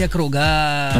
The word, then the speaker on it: округах: Ах, так вот в жизни бывает, что ударение в данном слове является подвижным. И в единственном числе всегда ставится на первый слог, а вот во множественном на окончание. Поэтому правильно говорить о кругах.